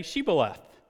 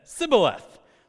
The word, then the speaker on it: Shibboleth: And when they said, "No," they said to him, "Then say Shibboleth." And they said, "Sibboleth," for they could not pronounce it right.